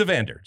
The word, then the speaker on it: Evander: Compatriot Evander, is that, am I pronouncing that right? I know how you Brazilians love to pronounce things differently. So the overarching question in MLS this year is who will finish second to Evander for MVP? – Okay. And it is Evander, just the way it is, right? – Yeah.